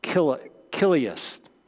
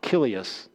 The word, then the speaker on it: Chiliasts: But almost all of the early church followers were what we call Kilius, C-H-I-L-I-A-S-T-S, but the C-H is pronounced with a K sound, Kilius.